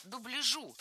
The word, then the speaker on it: дубляжу: Было сказано «дубляжу», и это правильно. Но всего лишь год назад я бы сказала, что это ошибка. Раньше норма была такая. Ударение нужно было ставить всегда на второй слог. Дубляжа, дубляжу, дубляжем. Но в новом словаре зафиксирована двойная норма. Поэтому и дубляжа, и дубляжа не ошибка.